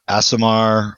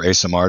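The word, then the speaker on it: Aasimar: And what they are is a conclave of Asimar, or Asimar, depending on your preferred pronunciation, prophets, soothsayers, and other fortune tellers and seers that all share a common vision for the future.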